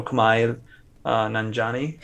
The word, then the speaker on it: Kumail Nanjiani: Or Kumail Nanjiani.